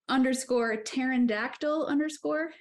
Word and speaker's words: pterandactyl: And my handle is _pterandactyl_. And it's spelled like P, the silent P, PTARYNDACTYL, pterandactyl.